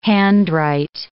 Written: handwrite

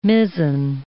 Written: mizen

